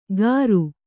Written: ಕರು

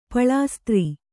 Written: ಪಳಾಸ್ತ್ರಿ

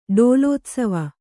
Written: ಡೋಲೋತ್ಸವ

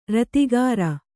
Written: ರತಿಗಾರ

